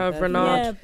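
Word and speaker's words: Hervé Renard: Herve Renard.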